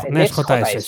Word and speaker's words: Next.js: Next.js, sí, eso.